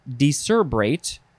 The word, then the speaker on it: decerebrate: Next is discerbate. There's an R in there. Decerebrate. There's an extra syllable.